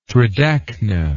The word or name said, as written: tridacna